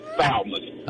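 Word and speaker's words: Thalmus: No, it's Salmon. – Oh, Salmon. Chuck, is that, how do you spell it? – It's T-H-A-L-M-U-S.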